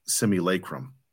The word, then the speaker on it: Simulacrum: It's spelled S-I-M-U-L-A-C-R-U-M. Simulacrum.